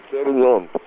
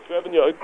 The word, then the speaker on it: Sauvignon: Sauvignon. – Sauvignon.